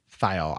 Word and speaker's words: thiol: Thiol. I think it should be. I just want to know how to pronounce it. I'm not an authority here, but yeah, I'm saying it's pronounced thiol.